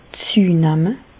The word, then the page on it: züenamme: Bas-Rhin; Reichshoffen